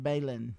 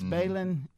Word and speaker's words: Belin: B-E-L-I-N, Balin. – B-E-L-I-N. Okay, Balin. – I got it. – It's Balin.